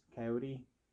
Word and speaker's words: Kioti: But then I also ended up working on uh, for Kioti tractors, Coyote. Um, it's spelled like Coyote, so I call it Coyote tractors.